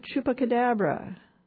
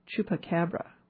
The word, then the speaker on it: chupacabra: There at 9 p.m., Holy Chupacabra. I don't think I said that right. Chupacabra.